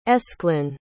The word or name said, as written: aesclin